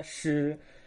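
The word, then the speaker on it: shi (狮): Y esta parte se llama shi. Entonces, juntos se pronuncia shi.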